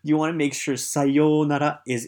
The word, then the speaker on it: sayonara: For example, I once helped a businessman who, after saying goodbye to somebody, said sayonara. Sayonara. Now, make sure you have the long O sound.